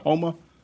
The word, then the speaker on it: Homa: H-O-M-A. Is it Homa? – Max Homa.